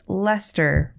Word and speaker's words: Leicester: Lester is spelled L E I C E S T E R, but it's pronounced Lester from everything I've heard.